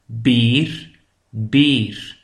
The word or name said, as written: de beer